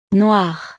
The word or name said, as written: noire